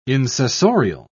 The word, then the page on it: insessorial: British English